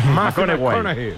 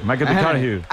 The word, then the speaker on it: McConaughey: Hay que ir corrigiendo la pronunciación. – McConaughey. McConaughey. Bueno, pues el Matthew se...